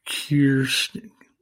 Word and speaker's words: Kirsten: It isn't Kirsten, it's Kirsten, yeah, Kirsten Powers.